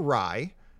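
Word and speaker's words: Rai: Anyway, this is I wasn't sure if it was Ray or Rye, and so I went on YouTube to see the pronunciation. And it is pronounced rye.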